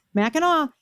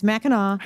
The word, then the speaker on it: Mackinac: Mackinaw. I remember when I was Mackinac. A kid. No, it's spelled Mackinac, Kevin. It's Mackinaw.